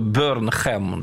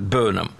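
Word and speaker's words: Burnham: Здесь сразу объясню, кстати, нашим слушателям, что он пишется по-английски, если читать вот буквы Бернхэм, да? – Да. – Но читается вот именно так, Бернум.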